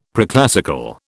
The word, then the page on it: preclassical: British English